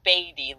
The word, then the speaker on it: Beatty: yes, it's Gayle Beatty, like Warren Beatty, the actor.